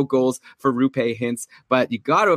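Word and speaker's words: Roope: But apparently it's Rupe, just like Lupe, the cleaning lady who George hooked up with on Seinfeld.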